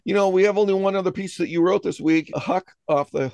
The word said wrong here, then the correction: Hauk: Hook, and I just mispronounced the name. It's Hauk, but anyway, Hauk Off the Hook.